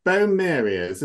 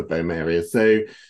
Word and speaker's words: Boehmeria: So the Boehmerias, is that correct pronunciation, Alan, do you think? – Yeah, I think it is.